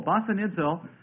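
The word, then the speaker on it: basanizo: This is spelled B-A-S-A-N-I-Z, long O. Basanidzo, something in addition to the birth pangs now.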